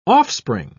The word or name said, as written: offspring